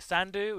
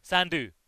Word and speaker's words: Sandu: But I'll be happy to accept Shamak Kar, Shamak Kar, as long as you get the surname right, Sandu, which is like two syllables, very, very easy. Sandu, easy, right? – Easy enough, there and that's go.